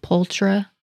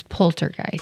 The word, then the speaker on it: poltergeist: And I'm sorry, I keep saying it wrong. I'm used to adding an R, like poltra, but it's poltergeist.